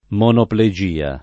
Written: monoplegia

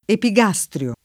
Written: epigastrio

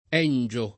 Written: Engio

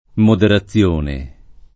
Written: moderazione

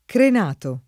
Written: crenato

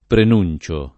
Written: prenunzio

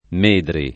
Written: Medri

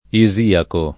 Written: isiaco

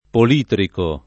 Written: politrico

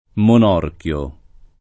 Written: Monorchio

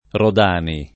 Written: Rodani

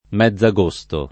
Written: mezz’agosto

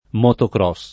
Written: motocross

